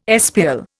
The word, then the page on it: espial: Indian